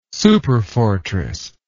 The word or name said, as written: superfortress